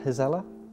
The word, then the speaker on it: gezellig: It's just how do I say that? Gezellig. – Gazelle. Yeah. – Is that good? – Mm-hmm.